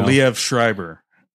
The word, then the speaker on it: Liev Schreiber: Oh, Liev Schreiber.